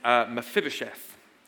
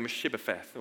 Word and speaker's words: Mephibosheth: I think I only did it once in the first service, and I keep going Meshibopheth or whatever.